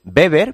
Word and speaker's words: Weber: Por cierto, se escribe con W. Nosotros decimos Weber, pero se escribe Weber.